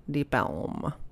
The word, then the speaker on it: Deep Ellum: So the Deep Ellum gets its name from Elm Street. – Right. – From being Deep Ellum Street. And it was just, you know, pronounced kind of Deep Ellum. And you can find different publications spelling it different ways. So the original spellings were Deep Ellum, E-L-M for Elm Street.